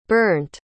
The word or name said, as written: Bernt